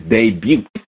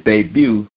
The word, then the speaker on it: debut: One time for Usain going out there changing sports and getting it in his first in his debut. Yeah, I pronounced the T. In his debut with his soccer club, the Mariners down there in Australia.